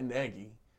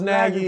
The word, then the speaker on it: Nagy: How Nagy, I gotta start saying his name right. Matt Nagy is calling the plays. – I thought it was Nagy. – It's Nagy. Everybody's calling him Nagy. His mama call him Nagy.